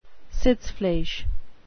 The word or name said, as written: sitzfleisch